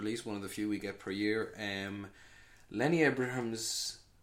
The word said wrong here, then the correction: Abrahamson: Am I saying that right? – Abrahamson. – Abrahamson. Abrahamson. God, lovely Irish name that.